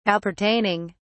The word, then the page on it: appertaining: British English